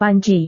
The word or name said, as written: panci